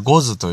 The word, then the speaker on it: 牛頭: えー、牛の頭と書いてゴズと読みますね。